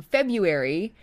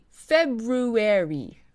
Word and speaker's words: February: So the word February is a great example. Instead of squishing the sounds together and saying February, like I would, in RP you would pronounce each syllable very clearly. February.